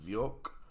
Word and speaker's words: Vyok: I recently discovered bands such as Kim Dracula and Warlord Colossus, and I am assuming you pronounce the other one Vyok, VYOK.